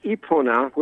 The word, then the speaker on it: Epona: It's pronounced, I think, Epona would be the correct pronunciation. It's the Gallo-Roman horse goddess, and they salute her with a parade, stable tours, and a blessing of horses and other animals.